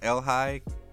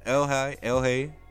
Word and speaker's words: ELHAE: I think it's pronounced Elhigh, capitals, all caps, E-L-H-A-E.